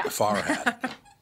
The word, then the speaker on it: Farhat: Farhat. Like a hat that's far away.